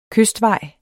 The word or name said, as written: kystvej